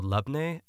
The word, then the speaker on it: labneh: It's called labneh. L-A-B-N-E-H is typically how it's spelled, but it's in a lot of like Mediterranean restaurants.